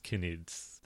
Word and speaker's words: Knids: That's K-N-I-D-S, but it's definitely pronounced Canids.